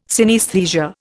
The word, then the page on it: synaesthesia: Indian